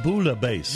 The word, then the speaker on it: bouillabaisse: It's National, I thought it was Billy Bassi Day, but you say that's a French word, and you pronounce it bouillabaisse. – Bouillabaisse. – Is it bouillah? You don't pronounce L's? – Yeah.